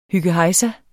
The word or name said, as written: hyggehejsa